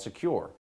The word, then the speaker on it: Sekur: That's why you need a new privacy and cybersecurity application called Secure. It's spelled S-E-K-U-R.